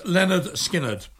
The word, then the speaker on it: Lynyrd Skynyrd: The album's called, pronounced Lynyrd Skynyrd. I always thought it was Lynyrd Skynyrd.